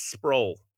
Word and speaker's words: Sproul: I'm like, so how do you pronounce this last name? He's like, it's Sproul.